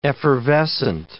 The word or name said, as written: effervescent